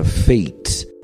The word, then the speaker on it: fate: A palavra gordo, por exemplo, fat, se você acrescentar a ela uma letra E no final, então lembra, fat se escreve F-A-T. – Fat. – Se você acrescentar a letra E no final desta palavra, vai ficar fate. – A pronúncia muda para fate, que quer dizer destino. Vamos ver a palavra torneira. Se escreve T-A-P.